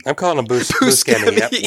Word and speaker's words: Buscemi: Buscemi? – He gets introduced as Buscemi, but when the topic gets brought up, Buscemi admits that while that pronunciation is correct, so is Buscemi.